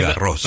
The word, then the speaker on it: Garros: Como ahora que se está disputando el torneo de Roland Garros, usted ya nos había explicado que no es Roland Garros. – No, es Garros. – Roland Garros, sí. Garros, claro, es como la calle Jean Jaurès. – Exacto. – Y esa ese final se pronuncia.